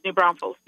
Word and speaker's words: New Braunfels: It's New Braunfels, yeah.